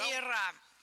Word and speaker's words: веера: Возможно, тебе будет интересно узнать, где правильно ставить ударение в слове «веер» в множественное число. Как ты думаешь? – Ну, на последнюю, ой, букву. Сейчас все будет плохо. Нет, угадал? – Да, веера.